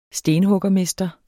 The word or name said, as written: stenhuggermester